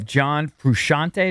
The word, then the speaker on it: John Frusciante: I know lots of people could do this, but there's a cool video of John Frusciante. Is that how you say his name? The Red Hot Chili Peppers guitarist. – That sounds right.